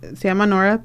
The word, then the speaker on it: NHORA: Tienen Facebook y Instagram, y se llama Nora, pero se escribe N-H-O-R-A, Southwest Idaho.